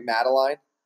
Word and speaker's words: Madeline: Madeline? – Yes, you are. – Okay, Madeline.